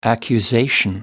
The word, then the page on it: accusation: American English